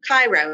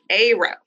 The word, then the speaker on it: Cairo: It's like the Egyptian city, pronounced Cairo. Oh. Cairo, Illinois. Cairo. Not Cairo, it's Cairo.